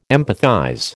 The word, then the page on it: empathize: American English